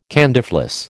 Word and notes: candyfloss: American English

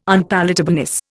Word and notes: unpalatableness: Indian

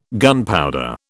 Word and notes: gunpowder: British English

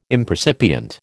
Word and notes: impercipient: American English

